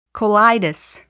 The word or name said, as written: colitis